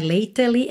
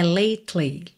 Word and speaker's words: lately: Mas olha, lately, você não pronuncia o E que vem depois da letra T, ok? Não é lately, é lately, lately.